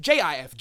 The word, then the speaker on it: GIF: Gizmodo, the creator of GIF, because that's what I'm going to call it for now, says it's pronounced GIF with a J. Okay. Well, it says there he is wrong. The title says he is wrong.